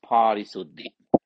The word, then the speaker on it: parisuddhi: Upekkha. UPEKKHA long A upekkha dash SATI sati dash, because this is one word, supposed to be one word, but so I separate PA long A RI SUDDHI parisuddhi.